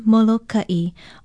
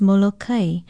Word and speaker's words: Molokai: Molokai is spelled M-O-L-O-K-A-Okina-I and pronounced Molokai, although there are those that argue the correct pronunciation is Molokai.